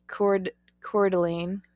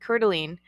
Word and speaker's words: Coeur d'Alene: Coeur d'Alene. That's how you say it. Coeur d'Alene.